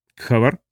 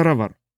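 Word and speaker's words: Ghawar: «Кстати, произносится не так. Сочетание «гх» в арабской транскрипции произносится как гутуральное «р». Он продемонстрировал, закатив звук далеко в гортань. «Не спрашивайте, почему. Система идет от англичан. Как бы то ни было, поле называется «равар».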